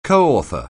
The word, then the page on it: coauthor: anglais